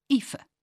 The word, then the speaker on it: Aoife: Which brings me to the thorny matter of how to pronounce any name with the letters A-O-I in it. Aoife, for example. Saoirse. Caoimhe. Naoise. The A-O-I sound in Irish is pronounced like a double E in English, and people have got used to that.